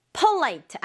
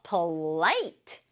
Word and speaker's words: polite: 친구들 느끼듯이 강조를 light 부분에 줘야 돼요. polite 아니고 polite. 한 번 더. polite. 아, 요렇게 polite라고 발음해야지 정확한 발음이다.